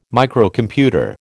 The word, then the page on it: Microcomputer: American English